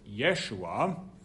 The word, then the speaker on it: Yeshua: And this name later on will be pronounced in Aramaic, so centuries later, as Yeshua, and then presented in the Greek New Testament as Jesus.